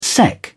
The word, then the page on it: sec: British English